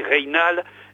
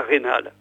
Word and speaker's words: Reynald: Alors Reynald s'écrit avec un Y, et donc en sarladais, je pense qu'en bergeracois aussi, on prononce le Y, c'est-à-dire ça fait Reynald et non pas Reynald.